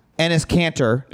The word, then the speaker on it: Enes Kanter: I would like to give a dunkie for most dedicated player to Enes Kanter. Did I say his name right? – Yes, you did.